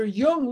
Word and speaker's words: Jung: J-U-N-G is Jung, pronounced Jung, not Jung or Jung, but Jung.